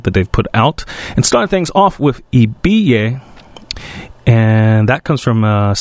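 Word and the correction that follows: Ibeyi: self-titled record, or I should say Ibeye.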